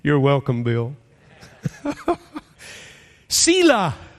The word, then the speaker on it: Selah: There is a little word all through the Psalms that is pronounced Selah.